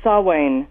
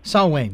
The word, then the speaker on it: Samhain: I was told recently it's Samhain. – Samhain, yes, I think that's correct.